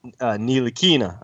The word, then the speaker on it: Ntilikina: And then the third tier, I've got Monk, Zach Collins, Donovan Mitchell, and Frank Ntilikina.